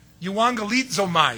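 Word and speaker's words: Euangelizomai (εὐαγγελίζομαι): Euangelizomai is actually how they say it.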